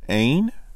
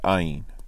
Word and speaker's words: ayin: It is interesting to note that the word ayin, which means nothing, ayin as in aleph yod nun, which is actually pronounced ain, sometimes you will see it transliterated as ayin.